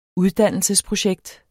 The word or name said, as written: uddannelsesprojekt